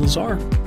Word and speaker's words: Lazar: Well, let's go right to today's discussion with Dr. Bob Wilkin and Sean Lazar. Here's Grace in Focus. – Welcome back to the show. My name is Sean Lazar. It rhymes with bizarre.